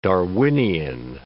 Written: Darwinian

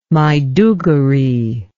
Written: Maiduguri

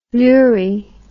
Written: Lurie

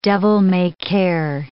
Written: devil-may-care

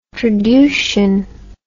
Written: traducian

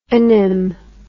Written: onym

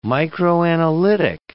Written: microanalytic

